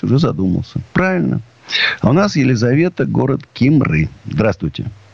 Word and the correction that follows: Кимры: Ну, немножко неправильно вы сказали. Кимры. – Кимры. Виноват. – Ударение на первом слове.